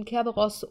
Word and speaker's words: Kerberos: Sie ist auf dem Schiff die Kerberos. Wird das ausgesprochen? Ähm, Kerberos, ja,